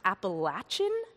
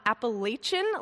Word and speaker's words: Appalachian: And apparently it's pronounced Appalachian, not Appalachian, like us West Coasters usually mispronounce.